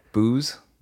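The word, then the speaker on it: Boos: How do you spell it? – B-O-O-S, I think. Just Booze. – Booze. – Booze Philly cheesesteak. – I was thinking like booze, like a drink.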